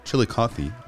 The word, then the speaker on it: Chillicothe: And yeah, you nailed the pronunciation of Chillicothe just perfectly.